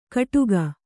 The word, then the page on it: ಕಡುಗ: kannada